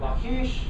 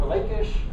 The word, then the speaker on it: Lachish: It's called, uh, you can say Lachish or Lakish, however you want to pronounce it.